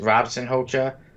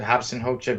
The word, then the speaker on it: Robson Rocha: Um, many may read and, and, pronounce his name as Robson Hocha or Robson Rocha, but I pronounce it Hobson Hocha because he is Brazilian, and a lot of Brazilians speak Portuguese, and their R's are pronounced like H's, so that that is why I do say R.I.P.